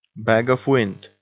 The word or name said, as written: bag of wind